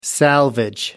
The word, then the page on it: salvage: American English